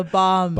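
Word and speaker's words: balm: It's not a bomb. The L is silent. It's a bomb. – Bomb? Like, I think B-O-M-B when you say bomb. – B- B-O-M-B.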